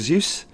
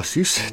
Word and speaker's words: Seuss: That is Dr. Seuss, or Seuss, depending on how you want to pronounce it.